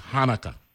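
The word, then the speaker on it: Chanukah: He let me know it's Hanukkah, right? Regardless of the spelling, right? The C is silent.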